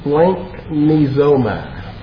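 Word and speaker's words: splanchnizoma (σπλαγχνίζομαι): It's quite a word in the original. It's pronounced splanchnizoma.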